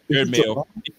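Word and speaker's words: Jerod Mayo: I'm saying Jared Mayo. Am I saying that right? Because it sounds – yep, Jared Mayo. Gerard. Did I say Gerard Mayo?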